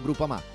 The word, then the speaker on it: Groupama: DJ Biancorosso, Groupama, si pronuncia Groupama, di Paolo Felicetti.